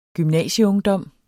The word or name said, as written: gymnasieungdom